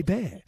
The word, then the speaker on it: Hebert: The quarterback of the Saints is Bobby Hebert. You know, it's spelled Herbert. – H-E-B-E-R-T.